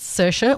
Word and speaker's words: Saoirse: It's Saoirse, almost like, I think identical to Saoirse Ronan, the actress.